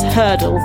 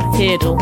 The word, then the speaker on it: Heardle: I've just also worked out that it's Hurdle, not Heardle, which is what I've been calling it all day. So thank you, Graham, for correcting me there. – Oh. – To rhyme with Wordle.